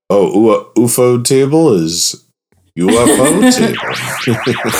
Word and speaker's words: Ufotable: Yeah, good on UFO Table I've gone back and forth with how to pronounce the studio's name. Ufotable or UFO table? – I think it's Ufotable.